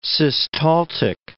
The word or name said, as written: systaltic